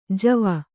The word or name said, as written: ಜವ